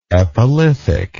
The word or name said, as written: epilithic